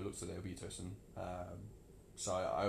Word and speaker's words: Tosun: And then the other sort of two were Tosun and... Is that how you pronounce his name? – Yeah.